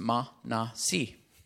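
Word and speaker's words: Manasi: His name was Ma-Na-Si. Three syllables.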